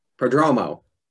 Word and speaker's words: Prodromou: Prodromou.